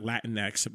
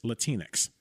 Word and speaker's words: Latinx: And for many people who don't know how to pronounce it, it's not Latinx, but it's Latinx.